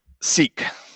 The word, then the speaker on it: Szeak: It's an interesting name, S-Z-E-A-K. How do you say that? – Seek.